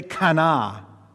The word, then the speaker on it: kana: Say kana. Kana. Okay? Say it again. Kana. Okay. You have to say it like that. Kana. Not like kana. All right? Yeah, it's kind of like a growl.